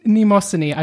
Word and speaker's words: Mnemosyne: Because they didn't say Mnemosyne. They said something like Mnemosyne, like something completely out there, hard pronouncing the M and the N at the beginning.